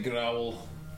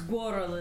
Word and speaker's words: Gwarl: Keep wanting to say growl. Anyway, sorry. – It's Gwarl. It rhymes with squirrel.